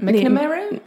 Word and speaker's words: McNamara: en ik hoop dat ik haar naam nu juist ga uitspreken. Michelle McNamara. – Ja, ik Mc, McNamara? – ja, ja wat jij zegt.